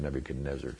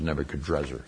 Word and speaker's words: Nebuchadnezzar: The N sound and the R sound are actually interchangeable in a lot of these languages, and so we really think his name was Nebuchadnezzar, so that's why I'm saying Nebuchadnezzar. But sometimes they spell it as Nebuchadrezzar. It's something about the Semitic languages. The N and the R can often switch places.